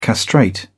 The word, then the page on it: castrate: British English